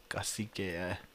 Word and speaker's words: Cacique: No, because I was like pronouncing it with like the K at the end, but it's not even pronounced that way. It's like Kosike. – Kosike. – I don't know. – Yeah, that's how it looks, but it's pronounced like... It's pronounced kind of white. – Cacique. – Let me see pronunciation. You know, I see. I already googled it. I knew. It's just cacique.